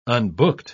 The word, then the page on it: unbooked: British English